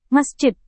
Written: masjid

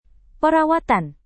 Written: perawatan

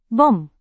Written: bom

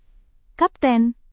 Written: kapten

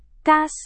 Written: kas

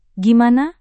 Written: gimana

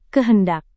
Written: kehendak

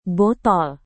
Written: botol

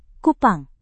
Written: kupang

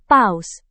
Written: paus

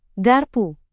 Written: garpu

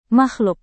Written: makhluk

